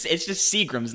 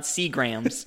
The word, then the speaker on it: Seagram's: It's just Seagram's, not Seagram's.